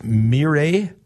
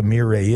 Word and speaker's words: Mirai: It's called a Toyota, I think it's pronounced Mirai. It's a Japanese word, M-I-R-A-I, a Toyota Mirai.